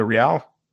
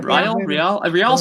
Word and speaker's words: Rial: I'm going to mispronounce her last name, but Monica Rial. – I think that's right. – Yeah. – Rial sounds right.